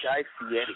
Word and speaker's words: Fieri: He says his name is Guy Fieri. And he pronounces it specifically like that.